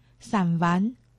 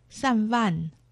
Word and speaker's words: 三湾: 客家话四线腔发音“ 散丸”，海陆腔发音“ 散万”。